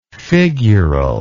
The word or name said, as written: figurally